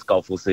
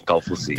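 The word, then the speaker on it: Kaufusi: Well, yeah, to be political, yes, it is Galfusi, because in Tongan they pronounce it the K as a G, so it's Galfusi.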